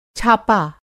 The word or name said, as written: छापा